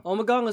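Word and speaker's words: Ommegang: Omagong is a brewery made that's run by monks. – Oh. And it's pronounced weird because it's like spelled like Omagang.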